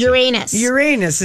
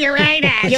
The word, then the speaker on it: Uranus: How do you say it? – Uranus. – If you want to be really fancy. – Uranus is what every fifth grader learns.